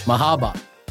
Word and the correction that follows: Merhaba: it's Merhaba. – Merhaba, oh, sorry.